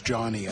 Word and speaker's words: Jawny: It's spelled J-A-W-N-Y, and it's short for Johnny Utah. So, yeah, so it's pronounced Johnny, I guess. Anyway, as in Johnny.